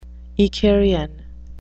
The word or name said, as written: icarian